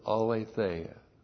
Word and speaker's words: aletheia: And we finished 3 John on Thursday night, and throughout that little tiny epistle is the word aletheia. A-L, long E-T-H-E-I-A. Aletheia.